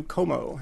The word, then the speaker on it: Comeau: Comeau, and my last name is spelled C-O-M-E-A-U.